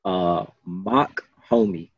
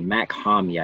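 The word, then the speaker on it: Mach-Hommy: It's spelled M-A-C-H, then it's a hyphen, and then H-O-M-M-Y. It's like Mac Homie, I think.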